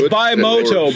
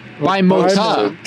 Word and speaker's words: Bimota: Can you spell the M-A-N-T-R-A. Mantra. 1994. – The R is silent, like the P in swimming. – It's Bimota. Bimota. – Bimota.